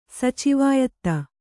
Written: ಸಚಿವಾಯತ್ತ

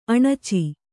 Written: ಅಣಚಿ

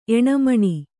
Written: ಎಣಮಣಿ

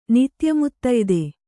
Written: ನಿತ್ಯಮುತ್ತಯ್ದೆ

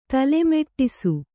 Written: ತಲೆಮೆಟ್ಟಿಸು

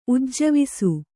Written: ಉಜ್ಜವಿಸು

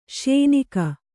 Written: ಶ್ಯೋನಾಕ

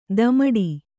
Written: ತಮ್ಮಟ

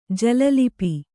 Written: ಜಲಲಿಪಿ

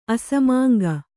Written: ಅಸಮಾಂಗ

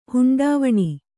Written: ಹುಂಡಾವಣಿ

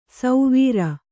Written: ಸೌವೀರ

